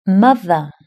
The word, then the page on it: mother: British English